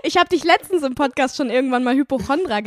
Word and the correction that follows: Hypochonder: genannt und danach habe ich gegoogelt ähm, und es heißt Hypochonder. Hypochonder. Ich hab's falsch gesagt.